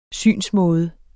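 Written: synsmåde

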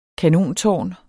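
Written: kanontårn

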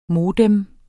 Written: modem

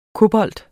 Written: kobolt

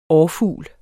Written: årfugl

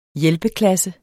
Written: hjælpeklasse

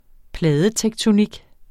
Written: pladetektonik

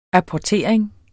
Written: apportering

